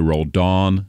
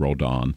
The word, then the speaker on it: Roldan: I hear Roldan, and it's Roldan. And it's subtle, but, you know, at least going off the phonetic pronunciation guides, that's how he wants it.